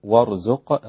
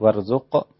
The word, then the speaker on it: وارزق: خلي بالك وارزق، الراء مفخمة مش وارزق.